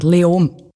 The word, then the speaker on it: Leoben: Und da gibt es die Stadt Leoben, ich spreche sie wahrscheinlich falsch aus. Spricht man es Löwen aus? Wie spricht man das überhaupt aus? – Eigentlich fast kein Konsonant. Leom.